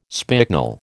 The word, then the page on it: spicknel: American English